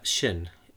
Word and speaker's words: Xin: But Xin, X-I-N. – Sounds pretty good.